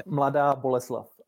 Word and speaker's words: Mladá Boleslav: Mladá Boleslav.